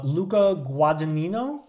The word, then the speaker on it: Guadagnino: A 2024 movie, Challengers, directed by, am I going to pronounce this right, Luca Guadagnino? – Perfect.